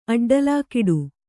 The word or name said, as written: ಅಡ್ಡಲಾಕೊಡೆ